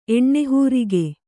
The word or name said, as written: ಎಣ್ಣೆಹೂರಿಗೆ